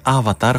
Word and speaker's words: AVATR: Έχει το όνομα Avatar ή διαβάζεται Avatar, χωρίς ωστόσο να υπάρχει τρίτο α στο όνομα της. Είναι AVATR.